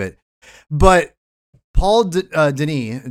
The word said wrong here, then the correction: Dini: I'm not sure how you pronounce the last name. – Paul Dini. – I want to call him Denis, but yeah, Paul Dini, the obviously the famous writer of Batman, of animated series, created Harley Quinn.